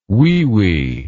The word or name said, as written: weewee